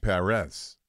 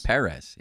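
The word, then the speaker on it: Pérez: Remember how to pronounce his name? – Martin Perez. – No. – Perez. – Martin Perez, yes.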